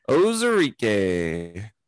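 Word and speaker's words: Onwuzurike: Osorike. See, there's a silent N in there. That's what got me.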